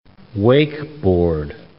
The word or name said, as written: wakeboard